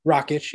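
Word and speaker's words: Rakić: So yeah, I think of I think of the four Jan Rocket Rockich. How do you say his name? – Rockich.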